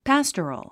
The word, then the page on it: pastoral: pǽstərəl; パストロゥ